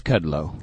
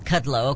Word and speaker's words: Kudlow: How do you pronounce his name? He has a show now on Fox Business. Took the place of Lou when I liked Lou. – I think it's Kudlow. – Kudlow, okay.